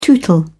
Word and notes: tootle: British English

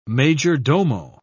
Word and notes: major-domo: British English

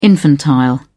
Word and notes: infantile: British English

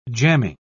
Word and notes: jamming: British English

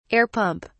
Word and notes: air pump: British English

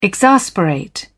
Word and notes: exasperate: British English

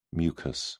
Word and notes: mucous: British English

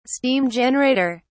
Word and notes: steam generator: British English

